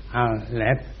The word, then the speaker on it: Aleph: The P is aspirated and becomes F. Aleph, Aleph.